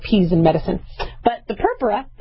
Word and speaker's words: purpura: Okay, you know, we don't want anybody to spontaneously bleed, but we call those bruises in medical terminology purpura or purpura. All right, I always put my accent on the wrong thing, but I called it purpura, and I think they corrected me as purpura.